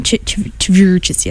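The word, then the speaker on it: Chvrches: then followed by "The Mother We Share" by Churches. – Yes. – And then Churches. – Churches.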